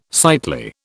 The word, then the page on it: sightly: British English